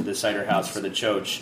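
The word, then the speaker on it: txotx: Well, I've already kind of touched on your cider pilgrimages, but I know there's something, I'm going to mispronounce it. Choach? – Yeah, no, that's right. Choach is right.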